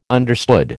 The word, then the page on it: understood: American English